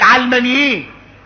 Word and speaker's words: علماني: يعني علمانيين، او اللي بيسموه دلوقتي علمانيين. اصل العلماني راجع الى كلمة العالم، علماني، النطق الصحيح لها علماني،